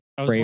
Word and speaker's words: Fravor: I was wondering if it was possibly spelled like Favre, like Brett Favre. – I keep saying it wrong. FRAVOR. – Oh, so it's like Fravor.